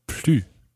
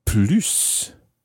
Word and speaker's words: plus: J'en veux plus. So when you pronounce the S of plus, it means more. And when you don't pronounce the S, it means no more.